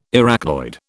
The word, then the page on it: Arachnoid: British English